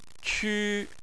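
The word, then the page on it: qu: tone 1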